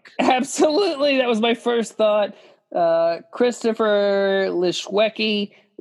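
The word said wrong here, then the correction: Lischewski: How do you say that name? Leshewski. Oh, man, even better. Leshewski, yeah, sounds like something you would name a pet tuna.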